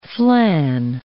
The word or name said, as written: flan